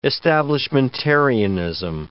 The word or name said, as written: establishmentarianism